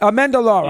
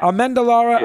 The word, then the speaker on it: Amendolara: Amendolara. Yeah. Amendolara.